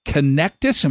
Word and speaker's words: Connectus: So, Michelle, the reason you're here is you are one of the directors of Connectus.